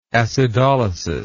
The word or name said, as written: acidolysis